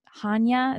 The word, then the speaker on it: Hanya: A Little Life by Hanya. Is that how I say her name? – Yes, I believe it is.